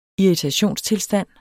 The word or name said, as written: irritationstilstand